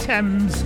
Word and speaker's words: Tems: That's spelled T-E-M-S, Thames, and it's called Me and You.